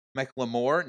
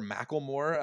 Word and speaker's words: McLemore: He was very clear about pronouncing that last name with a McLemore, not like the white rapper Macklemore, as we're hearing all the time on broadcast at this point.